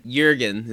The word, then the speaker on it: Jürgen: Jürgen is how it's pronounced. – Oh. – In German.